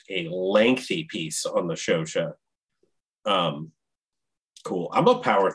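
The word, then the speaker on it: Chauchat: First of all, I just realized it's pronounced Shosha and not Shoshot, which is what I've been calling it for decades.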